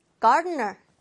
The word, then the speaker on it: gardener: gardener. 따라해보세요. gardener. 자, 이게 막 굴리는 발음. R이 두 개나 들어가서 발음하는 게 쉽지가 않아요.